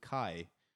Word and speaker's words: Khai: And then next, what we've got is an artist called Kai. So it's spelled K-H-A-I, and it's called M-A-D.